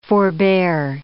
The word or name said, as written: forbear